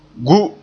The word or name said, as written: gu